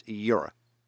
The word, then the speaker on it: Jera: It's like the fantasy world that I create stories in is spelled J-E-R-A, but it's Yura.